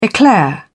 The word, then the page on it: eclair: British English